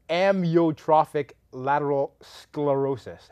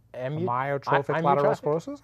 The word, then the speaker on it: amyotrophic lateral sclerosis: I want to say amyotrophic lateral sclerosis. Am I saying it wrong? – You're not terribly far. I think it's I think you enunciated the first one a little too long.